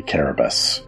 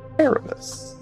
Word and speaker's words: Carabus: You can call me Carabus. Carabus. All right. With a C in that.